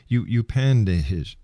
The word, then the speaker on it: Upanishads: In your book, you've referred to many times the ancient texts such as the I Ching and the Upanishads.